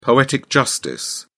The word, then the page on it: poetic justice: British English